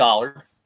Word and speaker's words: Allard: It's a minor move, but who is Kolby Allard? I got to learn how to say his name right. I believe it's Allard like Mallard.